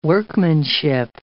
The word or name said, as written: workmanship